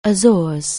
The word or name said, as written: Azores